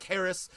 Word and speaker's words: Haracz: Like I said, my name is Chef Mike Haracz, and it's spelled H-A-R-A-C-Z.